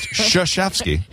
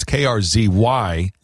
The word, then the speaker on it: Krzyzewski: It starts with it's pronounced Krzyzewski. – I don't know. Well, maybe someone can call and explain it. – Yeah, I'm sure there's. By the way, it's Krzyzewski, Krzyzewski, Coach K.